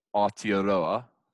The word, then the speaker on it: Aotearoa: Aotearoa, to pronounce it correctly. I actually did pronounce it correctly that time.